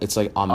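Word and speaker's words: Aimé: It's not Amy. It's like because it's French. It's like Ame or something.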